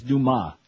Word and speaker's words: Dumas: Actually, his name is Dumas.